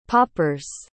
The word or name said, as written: poppers